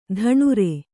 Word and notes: ದಿನಾರು: kannada

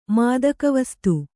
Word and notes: ಮಾದಕವಸ್ತು: kannada

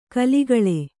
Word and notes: ಖುಲಿಖುಲಿ: khulikhuli

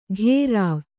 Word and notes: ಘೇರಾವ್: Kannada

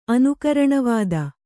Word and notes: ಅನುಕರಣವಾದ: anukaraṇavāda

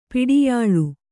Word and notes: ಪಡಿಯಳೆ: paḍiyaḷe

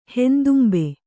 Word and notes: ಹೆಂದುಂಬೆ: Kannada

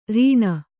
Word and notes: ರಿಣ: riṇa

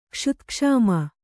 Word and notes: ಕ್ಷುತ್ಕ್ಷಾಮ: kṣutkṣāma

